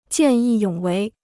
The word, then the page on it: 见义勇为: jiàn yì yǒng wéi